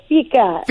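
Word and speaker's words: fika: It's FICA. – FICA.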